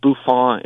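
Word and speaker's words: bouffon: Yeah, the Buffon is, like, the hoity-toity French pronunciation of buffoon.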